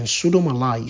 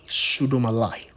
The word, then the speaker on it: pseudomallei: Bocoderia is spelled B-U-R-K-H-O-L-D-E-R-I-A, Bocoderia. And then pseudomallei is spelled as pseudo, P-S-E-U-D-O, and then M-A-L-L-E-I, pseudomallei, right?